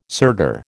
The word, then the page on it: sirdar: American English